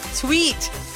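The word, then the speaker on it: TWS: There's so many posts already, so many reels in YouTube saying, this is how you pronounce it. Tweet. Even here, it says Tui. Noise. I heard it's pronounced as tweet.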